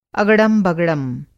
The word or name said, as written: अगड़म-बगड़म